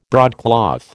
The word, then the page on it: broadcloth: American English